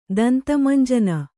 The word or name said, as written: ದಂತಮಂಜನ